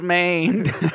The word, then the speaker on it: Mane: We had Taylor Mann. Or do I need to say Maine? – Is it Maine? It's probably Maine. It's spelled M-A-N-E. – It's Maine.